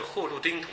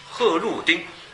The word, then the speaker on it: 贺绿汀: 当然为了带动他们检讨，请几个老革命知识分子做领头羊，领头羊了、啊、请了几个老知识分子，一个是贺绿汀同志，贺绿汀啊，绿都绿，绿绿颜色的绿，发音读绿，贺绿汀，贺绿汀啊，上海音乐学院的院长，就是游击队之歌的那个作者，听过游击队之歌吗？啊，他是很早就到延安去，到解放区，到根据地去啊。贺绿汀先生，老革命，自我批判。